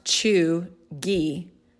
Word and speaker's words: cheugy: So it's pronounced chuggy, all right, but it's spelled C-H-E-U-G-Y, chuggy.